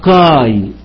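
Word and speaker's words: كاي (καί): قال لك إن الألفا يوتا تنطق آي آي ألف ي آي، يبقى كاي تنطق آي ألف ي، ودي متصورة وهتشوفوها. يبقى قال لك إن الألفا يوتا تنطق آي، يبقى ما أقول كبا ألفا يوتا تنطق كاي.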